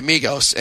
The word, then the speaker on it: Migos: So this guy from is it Migos? Am I pronouncing the name of the band correct? – Yeah, Migos, and yeah.